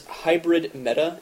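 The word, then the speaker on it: HybridMeta: My Miiverse name is Luvsion, which is L-E-U-V-S-I-O-N. Harrison, what's your Miiverse handle? – Mine is Hybrid Meta, and it's spelled H-Y-B-R-I-D as in dog, and M-E-T-A, all one word.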